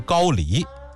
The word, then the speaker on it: 高丽: 还有一回啊，武则天的另外一个男宠张易之，张易之的兄长叫张同修，张同修在宴会上就拿这个杨再思啊开涮寻开心，说杨公的脸好像高丽人，我知道这个词儿正经的读音应该是读成高丽，也就是朝鲜历史上那个王朝啊。